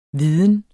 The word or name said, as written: viden